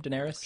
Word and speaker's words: Daenerys: Daenerys? – Sure.